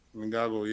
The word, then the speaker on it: Ngannou: You know, even as I said, him and the Francis, was it, how do you pronounce their last name? – Ngannou, yeah.